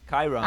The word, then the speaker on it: Chiron: What's that shit called? A Chiron? – Chiron. – I don't know. Oh, that's how you pronounce it.